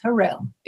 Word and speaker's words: Hurrell: Is it Harrell? Is that how you say your last name? – Yes, Harrell.